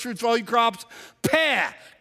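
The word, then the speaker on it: peh (פ): It's the 17th letter of the Hebrew alphabet, and it is pronounced in Hebrew, peh. We spell it in English, P-E-H.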